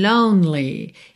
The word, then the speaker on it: lonely: Lonely, o E não é pronunciado, você vai do N direto pro L.